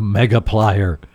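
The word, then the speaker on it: Megaplier: It's not a multiplier. It's a Mega Plier. You can't say it like, oh, you have a multiplier there. No, I have a Mega Plier. You say it like Batman.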